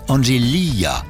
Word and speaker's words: Angelia: Elle a ajouté Oui, merci, mais ça se prononce Angelia.